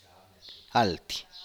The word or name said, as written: alty